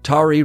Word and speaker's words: Tari: Tari, Rhymes with Jabari,